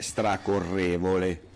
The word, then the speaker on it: stracorrevole: Con l'accento sulla E, stracorrevole, ok? – Stracorrevole.